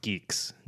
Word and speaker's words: GUIX: And that's pronounced geeks, GUIX. It's kind of geeky.